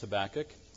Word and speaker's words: Habakkuk: One, I know that it's pronounced Habakkuk, and I'm almost certain to mispronounce it several times through the sermon.